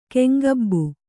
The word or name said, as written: ಕೆಂಗಬ್ಬು